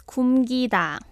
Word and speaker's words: 굶기다: Yes, the key verb is 굶기다. – 굶기다. – Yeah, it means to make someone or for someone skip a meal or meals. – Ah. – That's 굶기다.